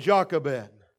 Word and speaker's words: Jochebed: There was a woman named Jochebed, and that, as far as anything I can look up, is the correct pronunciation of her name.